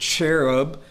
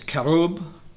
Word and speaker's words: cherub: And I will destroy thee, O covering, we pronounce it cherub, it's kerub in the Hebrew.